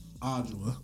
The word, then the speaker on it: Adjoa: A-D-J-O-A. Ajua.